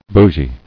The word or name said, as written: Bougie